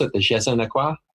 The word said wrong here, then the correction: je ne sais quoi: Je ne sais quoi.